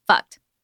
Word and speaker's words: FUCT: And yeah, it's pronounced fucked.